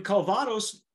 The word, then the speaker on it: Calvados: But a first for the brand, the blended aged tequila is then finished in Calvados casks.